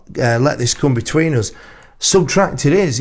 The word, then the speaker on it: SBTRKT: The artist name is pronounced Subtract, as in the arithmetic operator, and not by reading out the letters individually, as you did in this instance.